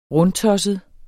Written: rundtosset

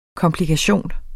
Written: komplikation

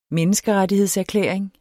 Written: menneskerettighedserklæring

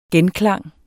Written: genklang

